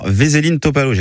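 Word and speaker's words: Veselin Topalov: Jeudi matin à Nîmes, un ancien champion du monde, le Bulgare Veselin Topalo.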